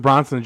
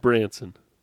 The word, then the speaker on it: Branson: Richard Branson just took an airplane to space. – You change his name every time you say his name. It's Richard Branson.